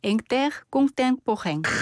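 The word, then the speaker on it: Intercontemporain: 죄송합니다. 한 번만 더 발음해 주세요. 너무 좋았습니다. – 네, 앵테르공통포행. – 이 정확한 이것까지 알 발음까지 하셨습니다. 앵테르.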